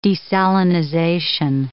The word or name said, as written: desalinization